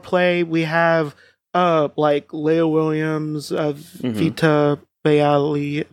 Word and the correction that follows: Ayala: I always forget how to say her last name. – Vita Ayala.